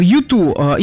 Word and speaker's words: U2: Итак, U2, именно так произносится название этого коллектива.